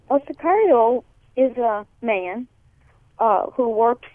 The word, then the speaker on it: Sicario: There's also a documentary, El Sicario, Room 164. Am I pronouncing that correctly? – Mm-hmm. – Sicario.